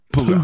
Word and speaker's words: Puga: It's not Puga. It's Puga. – Like Puga. – Steven, how do you say your name? – Steven Puga.